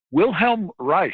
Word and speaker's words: Wilhelm Reich: Wilhelm Reich is the German pronunciation, which I've sort of gotten addicted to.